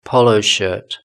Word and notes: polo shirt: British English

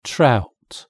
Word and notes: trout: British English

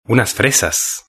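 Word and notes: unas fresas: European Spanish

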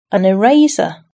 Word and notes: eraser: British English

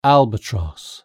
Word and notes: albatross: British English

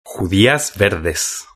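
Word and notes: judias verdes: Spanish (Spain)

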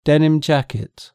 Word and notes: denim jacket: British English

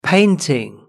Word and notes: painting: British English

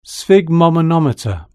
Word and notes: sphygmomanometer: British English

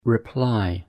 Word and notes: reply: British English

